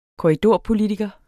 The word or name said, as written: korridorpolitiker